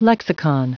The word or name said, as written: lexicon